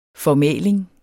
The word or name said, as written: formæling